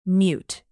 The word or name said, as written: mute